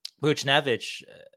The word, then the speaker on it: Buchnevich: Buchnevich wasn't on there.